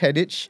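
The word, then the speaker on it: Headech: My surname is Headache, right? Properly pronounced Headache, but it's H-E-A-D-E-C-H. So it looks like Headache, sounds like Headache.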